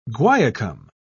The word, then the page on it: guaiacum: British English